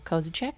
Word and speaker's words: Kozisek: Today, we have Joel Kozisek with us.